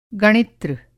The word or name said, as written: गणित्र